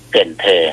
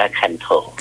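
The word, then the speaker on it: Cần Thơ: เกินเธอถ้าอ่านตามภาษาอังกฤษจะอ่านว่าคันโถอ่านตามภาษาอังกฤษจะอ่านว่าคันโถ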